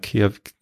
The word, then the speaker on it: Kehr: Kehr wieder, so wie K-E-H-R. Also nicht Care Englisch, sondern Care. – K-E-H-R. – Wieder. Also komm, komm zurück, sozusagen, Care wieder. Ach, Care wieder. Okay, jetzt habe ich kapiert. Care wieder.